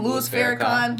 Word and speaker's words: Louis Farrakhan: Louis Farrakhan.